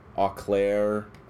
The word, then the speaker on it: Eau Claire: And I think he and the band are actually playing this live at the, I think it's pronounced the Eau Claire Festival in Wisconsin, which they curate and they're headlining.